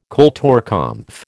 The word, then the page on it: kulturkampf: American English